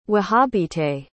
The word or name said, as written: wahhabite